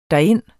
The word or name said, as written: derind